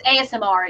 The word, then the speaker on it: ASMR: Now I reckon it wouldn't take long for them to do an Asmir, if that's how you pronounce it. I'll call it Asmir. – It's ASMR. It's just the letters. It's ASMR.